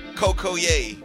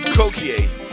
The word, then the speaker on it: Cocoyea: I said, "Bro, it's not Coco Yay. It's Cokie." But he said, he said it's pronounced, it's spelled C O C O Y E A, Coco Yay. I said, "No." it's Cokie. You know Cokie broom?